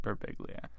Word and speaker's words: Birbiglia: I say it Birbiglia. You know, sometimes people come up to me and they'll be like, in Italy, it's pronounced Birbiglia. And I'm like, in America, you're annoying. That is the correct way. But we're Olive Garden Italian. We're not real Italian. So we say Birbiglia.